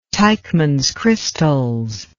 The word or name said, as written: Teichmann's crystals